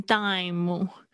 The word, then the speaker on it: dime: 接下来是 dime，dime，d i m e，特别注意一下那个 im 的声音，嗯嗯嗯，就是闭嘴巴。哦，也不要念成木的声音，不要念成 dime。嗯，dime。 OK，接下来是 nickel，特别注意一下这个 e l 的声音哦，它是一个这样子的声音哦。